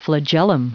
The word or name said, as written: flagellum